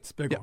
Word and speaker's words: Spiggle: Tom Spiegel, is it? To pronounce it correctly? – Spiegel, yeah. You got it. – Spiegel. Yeah. Spiegel? Or like Wiggle with an S-P.